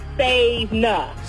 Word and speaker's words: Savina: Like, is it like seven, but like Savina? Am I saying it right? – It's like Savina.